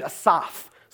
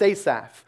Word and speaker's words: Asaph: So you've got David, you've got anonymous, and then you've got a distant third behind anonymous, a guy named Asaph, sometimes pronounced Asaph.